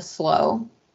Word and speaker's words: slough: I forget how it comes about, but Paul says he doesn't like the naturalists, which is what Ricardo was doing on the, is it pronounced slow or slough? – So I did the audiobook and they kept saying the slow.